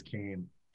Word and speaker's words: Keem: Actually, I see that Peter, Portage Pub Club, had a brilliant reply that said, smells like keem spirit. But the only problem is that beach in Achill is actually pronounced locally as Cain.